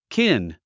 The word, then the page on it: kin: kín; キン